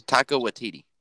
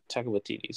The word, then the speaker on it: Taika Waititi: And I heard, I'm going to mispronounce his name, but Tika, who directed. – Yeah. I know who you're talking about. – The guy who directed, who's Taika Waititi. – Taika Waititi.